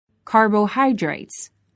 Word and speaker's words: carbohydrates: 但是呢我们在这句话当中的 quality 呢是做的它的形容词形式等同于 excellent, 修饰后面的 carbohydrates, carbohydrates, 这个词我们来说一下它的意思叫做碳水化合物它的重读音节呢是落在哪个音节上呢大家仔细听一下。 carbohydrates, carbohydrates, 没错就是 hydrate, high, 第三个音节的地方。